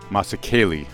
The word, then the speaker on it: Masakele: That song by the Golden Dawn Orchestra is called Masakele, as we can hear them pronounce it, and I could not remember, and by looking at it, I couldn't do it.